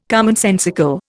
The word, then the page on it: commonsensical: Indian